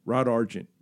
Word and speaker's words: Rod Argent: Rod Argent.